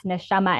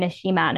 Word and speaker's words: Neshama: And like on my website, I have that exact Neshama and Neshima. Neshama is spelled in Hebrew and just the different, what do they call it when you like found it out? – Vowels. – So that's like where the difference is.